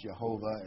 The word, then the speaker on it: Jehovah: And he said, the God, and by the way, the capital L-O-R-D, you know, Y-H-V-H, Strong says it's pronounced Jehovah, and they've anglicized it into the word Jehovah.